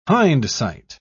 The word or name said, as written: hindsight